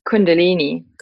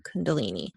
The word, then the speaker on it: Kundalini: Could you pronounce the name again? – Kundalini. – Kundalini.